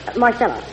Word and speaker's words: Marcella: Marcella. – Marcella. How do you spell it? – M-A-R-C-E-L-L-A.